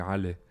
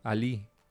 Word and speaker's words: Ali: It's very weird because when I grew up, it was like at home it would be Ali, and then people in school would be like Ali with like an accent on the I. – Right. – So people like in Spanish you pronounce it Ali, and I get here and everybody's like Ali.